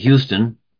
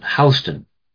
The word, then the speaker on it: Houston: We call our city Houston. There's a street, I guess, in New York City, spelled the same way, but they pronounce it Houston. Strange how things in the north and south are sometimes pronounced a little differently.